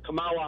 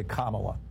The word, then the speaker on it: Kamala: So say this word, comma. – Comma. – Okay, now say Kamala.